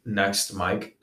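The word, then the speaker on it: NXT MIKE: I go under the name Next Mike.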